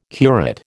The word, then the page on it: curate: American English